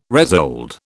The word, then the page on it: resold: British English